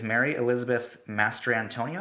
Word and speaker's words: Mary Elizabeth Mastrantonio: Our next nominee is Mary Elizabeth Mastrantonio.